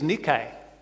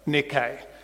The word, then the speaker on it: Nike: So people say Nike, some people say Nike. I'll put on my Nikes. The Greek actually is Nike, and it means conqueror.